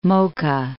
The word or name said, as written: Mocha